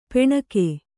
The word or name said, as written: ಪೆಣಕೆ